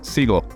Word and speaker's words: Siegel: Keith, is it Siegel or Siegel? – Siegel, correct. – Siegel, all right. Because in German, I would say Siegel, as you're spelled with an EI, from SUSE, which I haven't even said, about automation and probably some other topics.